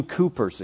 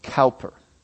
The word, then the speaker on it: Cowper: This was William Cowper's experience, or Cowper, depending on how you want to pronounce his name.